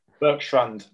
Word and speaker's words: Berkstrand: How do you pronounce it? – Berkstrand, like a Strand. – That the Berkstrand had collapsed.